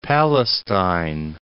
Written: Palestine

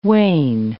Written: wane

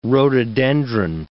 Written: rhododendron

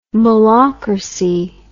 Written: mullahcracy

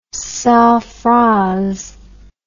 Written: sans phrase

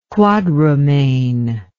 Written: quadrumane

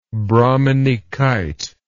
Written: brahminykite

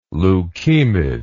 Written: leukemid

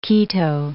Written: keto

